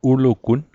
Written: ulugun

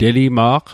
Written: telima'q

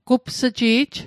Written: gups'ji'j

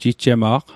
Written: jijjema'q

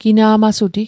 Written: gina'masuti

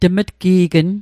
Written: tm'tqe'gnn

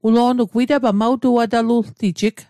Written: witapal

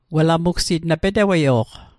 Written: p'teweio'q

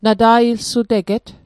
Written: nata'ilsuteget

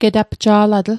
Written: getapja'latl

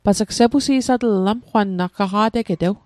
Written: sepusi'satl

